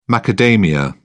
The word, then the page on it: macadamia: British English